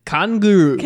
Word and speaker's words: Kanguru: Kanguru. – Kanguru. Can with a K though. K-A-N-G-U-R-U.